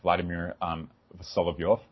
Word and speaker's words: Vladimir Solovyov: And before I'd ever heard of Dugin, I already had an interest in Russian mystics like Vladimir Solovyov.